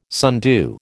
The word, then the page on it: sundew: American English